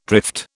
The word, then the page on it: drift: British English